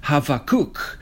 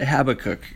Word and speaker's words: Habakkuk: Well, he's a prophet and there's a book in the Bible called Habakkuk, probably pronounced Havakuk, but we're just going to pronounce it Habakkuk for the purpose of this study.